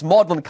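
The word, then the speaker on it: Magdalene: They spell it with an E at the end, but it's Magdalen College again.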